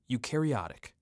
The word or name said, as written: Eukaryotic